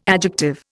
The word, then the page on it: adjective: Indian